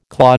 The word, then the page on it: claw: American English